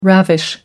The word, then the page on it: ravish: American English